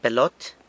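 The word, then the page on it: pelot: San Lucas Quiaviní Zapotec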